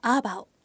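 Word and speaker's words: 阿爆: して耳にしているゴスペルっぽい曲ありますよねこの曲は台湾族のシンガーソングライターアーバオアーバオっていうのは漢字で書くとこう阿佐ヶ谷の「あ」に爆発の「爆」で「アーバオ」と読むんですけれどもこうパイワン語で歌っている曲なんですよ。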